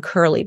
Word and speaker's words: Kurle: My name is Carolyn Kurle, but it's K-U-R-L-E.